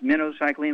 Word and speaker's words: minocycline: Well, it's actually pronounced minocycline, like the bait fish, minnow, little minnows, okay.